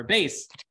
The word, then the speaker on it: Bet: Yeah, the Bet, exactly. Or Base, as some pronounce it, as I do.